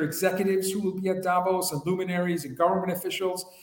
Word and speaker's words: Davos: Oh, Davos, for all those people who want us to pronounce it properly.